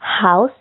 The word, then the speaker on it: house: Donc là, pour la maison, hein, on prononce house, c'est un son AO, ce n'est pas un O.